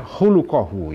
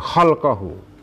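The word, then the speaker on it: hulukohu (خلقه): Bacanya hulukohu ya, bukan holkohu. Kalau kita baca holkohu artinya penciptaannya. Kalau kita baca hulukohu artinya akhlaknya insan tadi itu.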